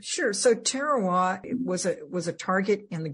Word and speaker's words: Tarawa: And I think Tarawa, is that how you say it? – Tarawa.